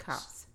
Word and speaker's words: Copse: Copse. It's pronounced Copse. – But it's spelled like Copse because it's a copse of, there used to be a copse of trees there.